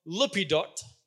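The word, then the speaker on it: Lappidoth: Deborah, the wife of Lippidot, that's how you pronounce that name, was a prophet who was judging Israel at that time.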